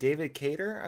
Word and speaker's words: Cater: It looked like David Cater, I believe is how you pronounce his last name.